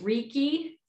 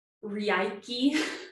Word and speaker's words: Reiki: And the first thing I want to say about Reiki is, well, it looks like it should be pronounced Reiki because it's R-E-I-K-I. – Or Reiki. – It's actually Reiki. And it's a Japanese word. Rei and ki is life force energy.